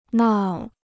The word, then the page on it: 闹: nào